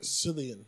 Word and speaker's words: Cillian: Cillian.